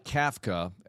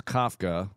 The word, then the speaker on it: Kafka: All right, Mike Kafka, and I believe that's the proper pronunciation. Not like Franz Kafka with Kafka, Metamorphosis.